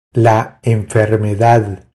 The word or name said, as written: La enfermedad